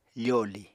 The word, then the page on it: foli: Apatani